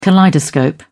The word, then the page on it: kaleidoscope: British English